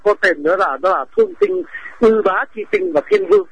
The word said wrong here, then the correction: Yết Bá: Chữ Ư Bá này đọc là Yết Bá.